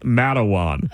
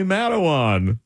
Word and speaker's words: Matawan: She's adding letters because she cannot figure out that this grouping of letters is pronounced Matawan.